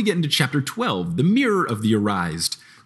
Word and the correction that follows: Erised: So it's Christmas. – It's Erised. – And Harry's super excited. Oh, Erised. Okay, good. I was like, I don't know how to say this. – It's desire spelled backwards because.